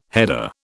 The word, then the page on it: header: British English